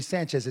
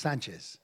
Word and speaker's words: Sanchez: It's not really Sanchez, it's Sanchez.